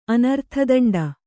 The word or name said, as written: ಅನರ್ಥದಂಡ